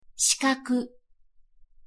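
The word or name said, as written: 四角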